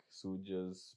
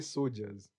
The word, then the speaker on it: soldiers: You say soldiers? – Yes, soldiers. Wait, how do you say it? – Soldiers. – Soldiers. – Yeah. – Did I say soldiers? – Yeah, you say soldiers. You missed the L.